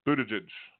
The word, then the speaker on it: Buttigieg: Mayor Pete Buttigieg. And I've heard, like, you say Judge and Buddha, then you flip it, Buttigieg.